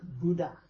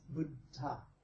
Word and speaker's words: Buddha: By the way, it's D-H, so that means it's a D and you blow out a little bit of air. It's not Dharma, okay? It's Dharma. And he's not Buddha. He's Buddha. Two D's and an H. That's how it's pronounced in proper Pali and Sanskrit.